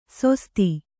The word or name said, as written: ಸೊಸ್ತಿ